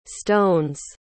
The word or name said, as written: stones